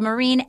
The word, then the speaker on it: Myrin: My last name is spelled Marine, M-Y-R-I-N. Anna Hosnier, H-O-S-S-N-I-E-H, and do hashtag W-Y-A-T-R.